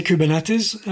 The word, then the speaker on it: Kubernetes: How do you pronounce that, by the way? – I say Kubernetes. I think it's a Greek word. It means the steersman, the rudder of the system.